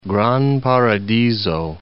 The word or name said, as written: Gran Paradiso